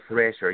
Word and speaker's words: Thrasher: And I'm like, no, Thrasher. You hear the th part, right? Th, th, th.